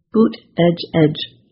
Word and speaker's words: Buttigieg: During his 2020 presidential run, his campaign made shirts with the pronunciation boot edge edge to help.